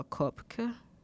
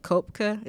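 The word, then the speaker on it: Koepke: Like Kopke, it's German, and I wanted to make sure that I pronounced it right. We're being very inclusive over here and understanding, so, and not tone deaf. Kopke, it's K-O-E-P-K-E, and Danielle is kind of pronounced, it's spelled differently.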